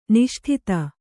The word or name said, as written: ನಿಷ್ಠತೆ